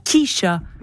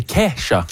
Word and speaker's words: Kesha: Il y a Kesha aussi. C'est pas Kisha. – Non, c'est Kesha.